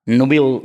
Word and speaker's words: Nubile: Que fíjate que yo sí hubo un tiempo que veía un porno muy chistoso que se llamaba, búsquenlo si quieren, se llama Nubil, Nubil Films, se escribe Nubile.